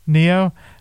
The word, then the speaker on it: Nioh: Then we have Noah 2, or Nio, N-I-O-H. – I think it's pronounced Neo. – Neo.